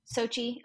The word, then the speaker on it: Sochi: So after the conclusion of the 2014 Winter Olympics in Sochi, is that how you say it? Is it Sochi? – Sochi. – Okay. I always get that one wrong.